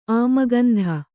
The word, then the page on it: ಆಮಗಂಧ: kannada